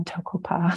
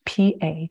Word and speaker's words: Toko-pa: And I'm pretty easy to find because there's really only one Toko-pa. So my name is spelled Toko-pa.